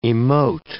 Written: emote

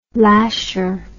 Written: lasher

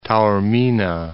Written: Taormina

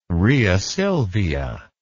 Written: rheasilvia

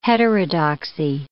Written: heterodoxy

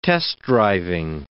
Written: test-driving

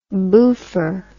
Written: boofer

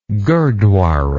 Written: gurdwara

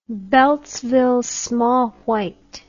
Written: Beltsville small white

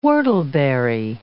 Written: whortleberry

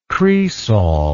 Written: cresol

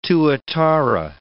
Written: tuatara

